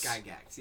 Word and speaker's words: Gygax: Gygax, yeah. – How do you spell Gygax? – G-Y-G-A-X. – Is that his real name? – I think so. – Three hard G's? Gary Gygax? – Yeah.